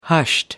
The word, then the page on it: hushed: American English